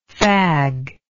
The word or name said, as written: faggish